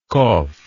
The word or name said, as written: kof